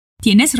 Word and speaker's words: tienes: Tienes razón? Vamos ver algumas coisas sobre a pronúncia nessa expressão. O som do T é igual na palavra... Tatu. A gente diz ti. Ti. Tienes.